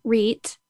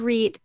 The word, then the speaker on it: Reat: I had my first child, Reet, and it's spelled R-E-A-T for your listeners if they're thinking, how did she spell that name? His name is Reet, and he was 4 weeks old when I left his father, and he was born in 1999.